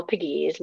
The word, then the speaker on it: Pegues: um, so you can find me on Facebook um, under Michelle Pegues.